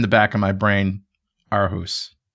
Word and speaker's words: Aarhus: And prior to joining Microsoft in 2005, he was an associate professor at the University of Aarhus. Did I say that right? – Well, that was beautiful.